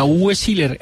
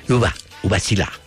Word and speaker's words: Uwe Seeler: Sí, a Uwe Seeler, el gol que le hace. – No, es que lo pronunciaste mal. Uwe Seeler.